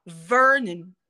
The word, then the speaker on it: Vernon: Então, de novo, é porque ver é a sua sílaba tônica. Esse, o, aí, quando a sílaba não brilha, ela reduz. Então fica assim, ó, Vernon.